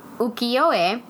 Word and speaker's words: ukiyo-e: And then that genre that that falls into is ukiyo-e.